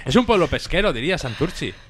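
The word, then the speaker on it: Santurtzi: hemos salido fuera, sobre todo, pues lo que más, porque también una vez tocamos en Santurce o Santuchu, ya no sé cómo se dice. – Santurchi.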